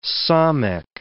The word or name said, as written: samekh